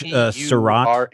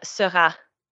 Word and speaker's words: Seurat: Yate or George Surratt. – S-E-U-R-A-T. – I think it's George Surratt. – S-E-U-L-A-T? – R-A-T. R-A-T. – Surratt.